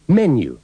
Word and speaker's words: menu: Notará que la palabra menú lleva el acento en la primera sílaba y no en la segunda. – Menu.